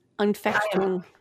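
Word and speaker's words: Anfechtung: It starts with an A. It's like Efutung or something like that. – Unfechtung.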